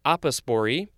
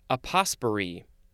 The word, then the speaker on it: apospory: First way is apospori. Second way is apospori. A-P-O-S-P-O-R-Y.